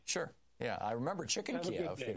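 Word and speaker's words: Kyiv: Tomorrow when you talk to Philip Idner, please, don't say Kiev, say Kiev. – Kiev is the Russian pronunciation. Kiev is the Ukrainian pronunciation.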